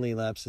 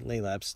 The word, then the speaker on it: Lelaps: They sound the same, but the Roman Lelaps is spelled L-E-L-A-P-S, where the Greek started with L-A-E.